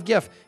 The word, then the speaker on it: GIF: It can be both pronounced, GIF or JIF, so you were correct.